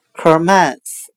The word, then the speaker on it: commence: 我们可以用谐音法来记忆这个单词 commence，它的发音很像汉语的客门始，客人的客，大门的门，开始的始。